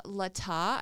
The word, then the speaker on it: Latah: On November 17th, the Latah, I think I'm pronouncing it right, but it's L A T A H, Lata, Lata, I'll say Lata because it sounds fancy.